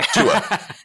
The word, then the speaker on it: Tua: It's Tua, right? – Yeah. – Tua. Yeah. We just call him Tua.